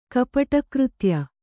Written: ಕಪಟಕೃತ್ಯ

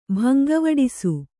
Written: ಭಂಗವಡಿಸು

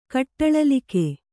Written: ಕಟ್ಟಳಲಿಕೆ

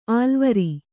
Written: ಅಲವರು

